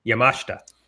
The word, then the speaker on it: Yamashita: You know, I say that name all the time, and I just said it wrong. It's Yamashita.